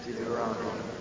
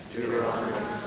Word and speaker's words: Deuteronomy: So the first five books of the Bible are Genesis, Exodus, Leviticus, Numbers, and this is hard to pronounce. Maybe we can learn it today. It's called Deuteronomy. Can you say that? – Deuteronomy. – Try to say it again. – Deuteronomy.